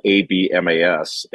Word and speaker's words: Abmas: Um, you remember Oral Roberts beat Ohio State a couple years ago, and they still have the same guard, Max Abmas, spelled A B M A S, and it's pronounced Abmas.